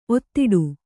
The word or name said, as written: ಒತ್ತಡೆ